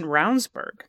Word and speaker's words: Ravnsborg: Raunsberg. I just learned how to pronounce that correctly, because it's not at all what it looks like.